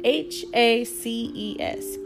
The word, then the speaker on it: haces: The H is silent in Spanish. So it's not ¿Qué haces? It's not hola. It's hola, it's ¿Qué haces?